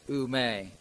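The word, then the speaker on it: ume (οὐ μή): The Bible word never here is really two Greek Bible words spelled O-U-M-E, and I believe it's pronounced ume.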